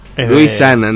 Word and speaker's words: Luisana: Luisiana Lopilato. – Luisana, no Luisiana. – Luisiana. – Se llama Luisana.